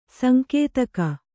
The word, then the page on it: ಸಾಂಕೇತಿಕ: sānkētika